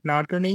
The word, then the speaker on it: Nadkarni: And I'm like, Nadkarni.